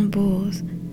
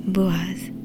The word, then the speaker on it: Boaz: De Rahab, Salma eut pour descendant Boaz, aussi prononcé Boaz.